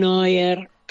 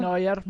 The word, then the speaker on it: Neuer: Todo lo que hizo Iker Casillas, Keylor Nava, Thibaut Courtois, Manuel Neuer. Así lo dije bien. – Muy Neuer, bien, Neuer. Neuer muy bien.